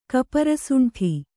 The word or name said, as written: ಕಪರಸುಂಠಿ